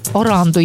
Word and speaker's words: Оранду: Оренду, власне, ні, Оранду її звуть, я думаю, не вже таке прізвисько.